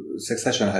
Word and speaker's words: Succession: Succession heißt es.